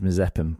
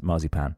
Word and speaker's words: Mazepin: I know his surname is Mazepin because we always pronounce it Marzipan, but he was driving the Mercedes,